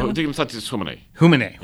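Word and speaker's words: Dignitatis Humanae: How do you pronounce that? – Dignitas Humanae. – Humanae, right.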